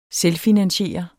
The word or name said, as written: selvfinansiere